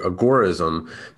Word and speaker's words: agorism: So the official pronunciation is agora or agorism, because it comes from the Greek term agora, which just means, marketplace.